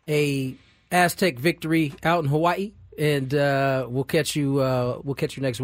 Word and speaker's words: Hawaii: Yes, because that is how it's said, Hawaii.